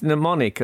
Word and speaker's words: mnemonic: I tried to pronounce the M in at the, because mnemonic, as you'll all know, is M-N-E-M and O-N-I-C. Mm. And the M-N at the beginning, I just had a go out loud of pronouncing the M. And it's almost impossible to pronounce the M at the beginning of mnemonic without following it with do do do do do.